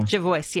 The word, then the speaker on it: Jervois: Is it Jervois or...? – Jervois, yes.